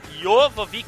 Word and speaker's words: Jovovich: A Mila Jovovich, como é que se pronuncia? Mila Jovovich? – Jovovich.